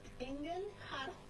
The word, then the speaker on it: Engelhardt: Mi apellido es Engelhardt, que es ángel de acero, y es alemán.